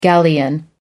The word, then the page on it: galleon: American English